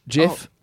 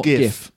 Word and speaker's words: GIF: It should be GIF. – Yeah. – Graphics. – Well, you say that though, but then you say. – But how many words are there that start with a G-I that are pronounced GI?